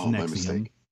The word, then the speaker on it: NXIVM: This is spelled differently. It's NXIVM, but it's Nexium.